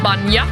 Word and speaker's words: bainne: Fjärde ordet, nej, tredje ordet är Firinne, och det skrivs F-I-R-I-N-N-E. – Ja. – Och sista ordet, som jag förstår man uttalar som typ Banja, som skrivs B-A-I-N-N-E. – B-A-I-N-N-E.